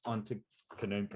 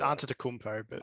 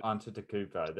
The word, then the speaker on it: Giannis Antetokounmpo: Yeah, Giannis Antetokounmpo. – It's Antetokounmpo. – Antetokounmpo.